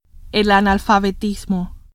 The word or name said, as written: analfabetismo